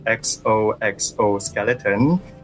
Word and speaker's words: XOXO Skeleton: And when you say it out loud, it also says exoskeleton.